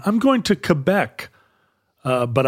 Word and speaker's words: Quebec: For a long time there was emphasis on saying Quebec because that would mean there would be one Ch instead of being K-K-K, it would be Ch-K-Qua. But in fact, that did not stick around. The official pronunciation is Quebec.